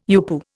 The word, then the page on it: UPU: Indian